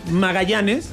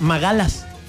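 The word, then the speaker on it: Magalhães: Hay un famoso defensor de Brasil ahorita que se apellida Magallanes. O sea, que para nosotros sería Magallanes. Uh-huh. Y, por ejemplo, estaba escuchando la pronunciación directa de él y es Magalas. – Ah. – Ajá, exacto. O sea, dice Gabriel Magalas.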